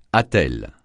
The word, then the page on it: attelle: francais